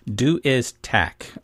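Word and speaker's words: Du Iz Tak: Now, the book you picked as one of your own particular favorite books for kids is a book called Do Is Tack by Carson Ellis. And I'm not really sure if I'm saying that right, but I think that's right. And this was published. – That sounds right.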